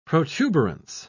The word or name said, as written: protuberance